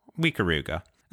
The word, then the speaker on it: Ikaruga: I don't know how Ikaruga, you actually pronounce it. – Ikaruga.